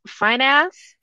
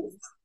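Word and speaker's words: Fiennes: How do you say this pen's name? – Fines. Or Fines.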